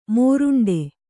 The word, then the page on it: ಮುರುಂಟು: Kannada